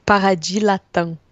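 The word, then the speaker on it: Paradis Latin: Mas como é que fala? – Paradis Latam. – Paradis Latam. Porra, nada a ver. – é estranho, Paradis, eles comem que, o S.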